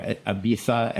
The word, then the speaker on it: Ibiza: I was in actually Ibiza, and I wanted to, or Ibiza as they pronounce it,